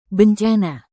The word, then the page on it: bencana: indonesian